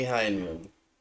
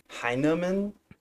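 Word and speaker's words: Hyneman: Now, I think you've mispronounced his name almost every single time you've said it. – How do you say it? – Jamie Hindman? – Heineman? – Yeah, there's an N there.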